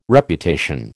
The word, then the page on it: Reputation: American English